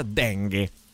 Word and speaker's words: dengue: scrive, sono anche argentina se mi, se mi gira sono anche altre cose, ma si pronuncia dengue. – Dengue. – Va okay. bene così, dengue.